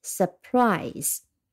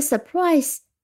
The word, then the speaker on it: surprise: 嗯，它的读音很简单，呃，两个音节，surprise。那么重音是在第二音节，s 就是 s u r 啊，这个 e 是用 u r 拼出来的，但是它是个短音，不是长音，surprise。那么这个 prize 是 p r i s e 呀，e 是不发音的，prize。再拼一遍，s u r p r。 i s e surprise，那 surprise 它作为名词呢，就代表呢是一个让人惊喜、让人惊讶、让人呃吃惊的消息也好，呃事情也好。